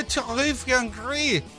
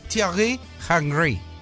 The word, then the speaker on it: Thierry Henry: Se dice Thierry Henry. – ¿Cómo? – Dímelo en francés. – ¿Cómo se dice? – Thierry Henry.